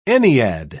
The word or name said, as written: ennead